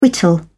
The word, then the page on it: whittle: British English